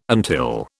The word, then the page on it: until: British English